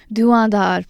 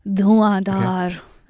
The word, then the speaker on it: Duandar: Duandar, let's pronounce it. So, you know, you see these Mughal words. I love pronouncing them because I can speak Urdu. So you've got to say it with a umph.